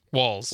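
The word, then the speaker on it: Walz: Walls.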